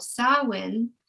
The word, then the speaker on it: Samhain: The festival that they would have is called Samhain, but it's spelt S-A-M-H-A-I-N.